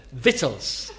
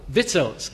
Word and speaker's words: victuals: How would you say V-I-C-T-U-A-L-S? So I thought, oh. I said, victuals. He said, vittles. So after tea, how do you say it? Vittles.